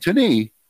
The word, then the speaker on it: Taney: Taney, T-A-N-E-Y. I know that's how it's pronounced because I've heard everybody say it. It's not Taney. It's not Taney. It's not Taney.